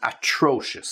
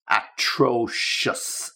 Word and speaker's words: atrocious: And the two alternatives that I have for you are terrible and atrocious. So just be careful with the pronunciation of that. Terrible, easy atrocious, atrocious. Okay, just to make sure you get the right stress.